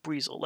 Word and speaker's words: Buizel: I think that's how they said it. Buizel. Like weasel. – That's how I would expect. Yeah, right. – Like, it's not like... – B-U-I-Z-E-L.